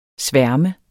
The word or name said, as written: sværm